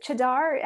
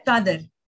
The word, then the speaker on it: chadar: But going back, and is it chadar? How do you say it? – Chadar. – Chadar.